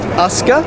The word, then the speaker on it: Asuka: So it's pronounced Uska. – Uskable. – But it's spelled A-S-U-K-A. – Yes, most people call it Asuka.